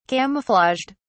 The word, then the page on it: camouflaged: British English